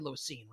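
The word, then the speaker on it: Lewis Cine: I'm pronouncing his name correctly, Lewisine, right? – Yep. – Lewisine.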